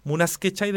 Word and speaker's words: Munas Quechay: Espero pronunciarla bien, porque se llama Munas Quechay, ¿sí? Sí, así se debe pronunciar. Munas Quechay de los Jarcas, y nosotros nos despedimos y nos escuchamos luego.